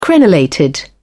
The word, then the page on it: crenellated: British English